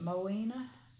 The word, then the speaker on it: Moina: She was talking about, how do you say her name? – Moena. – Moena. – Moena is a variation of the French word moine, little sparrow. – Little sparrow, okay. Mm-hmm. M-O-I-N-A, okay.